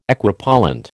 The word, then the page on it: Equipollent: American English